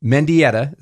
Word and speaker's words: Mendieta: His name is August Mendieta.